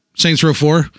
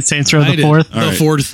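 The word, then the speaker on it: Saints Row IV: Did you say it properly? – Saints Row 4? – Yeah, I did. – Saints Row the fourth.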